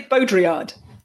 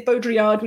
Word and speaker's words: Baudrillard: Baudrillard, how do you say his name correctly? – I say Baudrillard. Baudrillard. And I think a French person would say it differently. A French person might say Baudrillard or something like that.